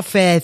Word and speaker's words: faith: Oui, ça s'écrit F-A-I-T-H. Je ne comprendrais jamais rien. – Non, mais Laurent, c'est la foi, fesse.